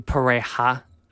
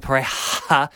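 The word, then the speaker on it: Pareja: Yeah, I've struggled my way through the last couple of weeks trying to say Pereja, as everyone else seems to,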